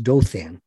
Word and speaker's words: Dotan: And this lab is run by a postdoc researcher, Ravit Dothan, D-O-T-A-N, pronounced Dothan.